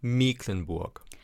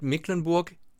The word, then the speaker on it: Mecklenburg: Und lustigerweise ist es so, dass, ich sag's jetzt mal, im Mecklenburger Plattdeutsch wird Mecklenburg M-E-K-E-L, also Mecklenburg geschrieben. Und dann eben auch, weil das C nicht steht, kurz ausgesprochen. Das heißt also nicht Mecklenburger Platt, sondern Mecklenburger Platt.